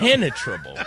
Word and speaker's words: impenetrable: Impenetrable. Impenetrable. – No, your emphasis is on the wrong syllable. – They were so dumb, they had face masks. And a chin strap. – Impenetrable.